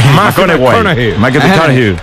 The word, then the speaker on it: McConaughey: ¿Matthew McConaughey? – Sí. – Hay que ir corrigiendo la pronunciación. – McConaughey.